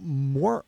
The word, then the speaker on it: mormal: What about mormal? – Mormal, M-O-R-M-A-L. – So it rhymes with normal, starts with an M.